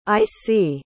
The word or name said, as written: icie